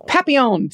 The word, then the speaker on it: papillon: Papillon. How do you say that? Papillon. Papillon. Papillon. Thank you. I knew that the LL was not a hard L, but I couldn't remember.